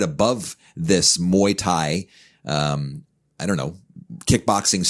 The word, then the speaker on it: Muay Thai: And yet now, eh, let me say if I pronounce this correctly, Muay Thai. – Muay Thai, yes.